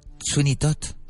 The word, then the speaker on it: Sweeney Todd: Sweeney Todd.